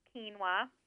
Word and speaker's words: quinoa: And it's spelled quinoa, quinoa, pronounced quinoa.